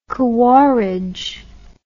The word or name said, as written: Khawarij